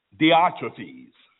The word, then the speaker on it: Diotrephes: But Diotrephes, who loves to have the preeminence among them, does not receive us. Diotrephes.